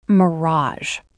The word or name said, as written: mirage